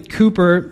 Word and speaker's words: Cowper: Now, William Cooper, which it's spelled like it looks like Cowper, William Cooper lived in the 1700s.